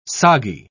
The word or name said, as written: soggy